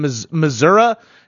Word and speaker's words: Missouri: Up from Missouri.